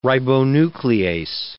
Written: ribonuclease

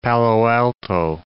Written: Palo Alto

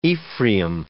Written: Ephraim

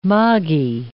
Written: moggie

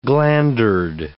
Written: glandered

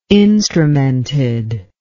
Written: instrumented

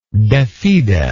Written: defide

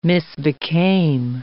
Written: misbecame

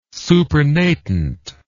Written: supernatant

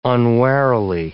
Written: unwarily

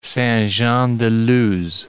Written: Saint-Jean-de-Luz

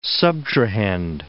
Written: subtrahend

